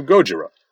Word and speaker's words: Gojira: In fairness, we do get a pretty good joke here, as newsman Kamen steals Audrey's report and states the monster's name to be Godzilla, as a mispronunciation of Gojira. Of course, this is then immediately undermined by Audrey's response of, "It's Gojira, you moron!" where she also mispronounces Gojira's name, but there you go.